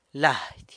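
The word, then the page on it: lady: San Lucas Quiaviní Zapotec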